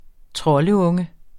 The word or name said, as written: troldunge